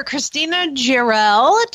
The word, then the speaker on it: Christina Jarrell: Well, I'd like to welcome Auditor Christina Jarrell to the show. Did I say that right, Christina? – Yes, perfectly.